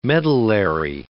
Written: medullary

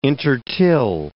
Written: intertill

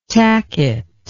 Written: tacket

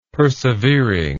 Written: persevering

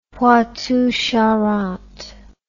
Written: Poitou-Charentes

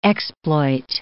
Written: exploit

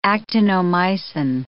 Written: actinomycin

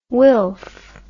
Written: wilf

